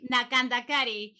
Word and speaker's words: Nakandakari: No sé si lo pronuncié bien, pero tú me puedes corregir. – Nakandakari. – Nakandakari. – Así es.